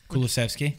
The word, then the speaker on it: Kulusevski: Kulusevski. – Kulusevski.